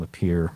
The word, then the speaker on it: Lapeer: but I fictitiously named the bus driver Kevin Lapeer because it rhymes with deer.